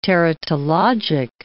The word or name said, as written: teratologic